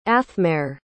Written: athmarr

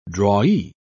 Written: drawee

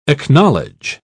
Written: acknowledge

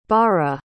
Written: baara

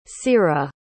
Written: cira

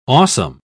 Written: awesome

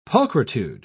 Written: pulchritude

